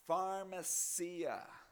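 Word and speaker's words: pharmakeia: It's translating a Greek word that's pronounced pharmacia.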